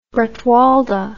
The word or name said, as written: bretwalda